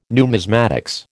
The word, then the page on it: numismatics: American English